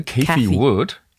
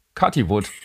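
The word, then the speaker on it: Cathie Wood: Kathy wird die, glaube ich, ausgesprochen. Ne? Kathy Wood. – Kathy Wood. – Ich sag Kathy. Kathy Wood.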